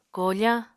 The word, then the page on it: kofañ: Apatani